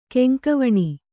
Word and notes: ಗಂಗಾವನ: Gaŋgāvana; Kannada

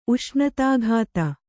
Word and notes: ಉಷ್ಣತಾಘಾತ: uṣṇatāghāta

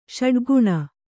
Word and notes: ಷಡ್ಗುಣ: ṣaḍguṇa

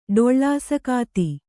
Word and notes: ಡೊಳ್ಳಾಸಕಾತಿ: kannada

